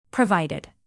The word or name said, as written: provided